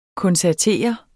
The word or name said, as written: koncertere